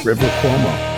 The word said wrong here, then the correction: Rivers: Mm-hmm. – Rivers Cuomo. – Did I say his name wrong already? Is it Rivers? – Rivers? – Rivers. – Rivers. Mm-hmm. Oh. – Rivers. Multiple. So. He's multiple Rivers.